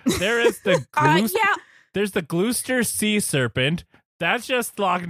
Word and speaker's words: Gloucester: I'm just that's Gloucester. – Okay. – That says Gloucester. That's in Massachusetts. – There seems like there's a lot of letters in there that aren't pronounced.